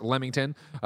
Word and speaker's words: Leamington: It is pronounced Leamington, Leamington. Yeah, okay, great.